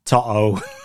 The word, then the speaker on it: Toto: To to Toto Toto Toto. It's like Otto, but with a T.